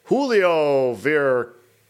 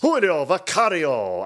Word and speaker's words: Julio Vicario: Classics 1 was won by Julio Vicario. I think I said that right. – Yes. – Julio Vicario. I got it. I can't trill the R.